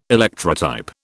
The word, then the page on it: Electrotype: British English